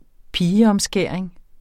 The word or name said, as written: pigeomskæring